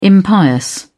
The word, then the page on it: impious: British English